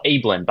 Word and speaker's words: Abeln: And this one as well, Abeln, by the way, is how you pronounce his last name. Found the pronunciation on the website.